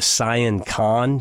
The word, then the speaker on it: Sian Ka'an: We went to the Sian Khan. You need to put that on your bucket list. It's pronounced S-I-A-N-K-A-A-N.